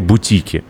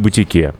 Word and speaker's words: Бутики: Давно хочу вам правильное ударение подсказать. Баре «Бутики», не «Бутике». И это первый подкаст, которым, кстати, мы записываем стоя.